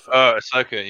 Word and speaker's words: Ahsoka: Ahsoka, yeah.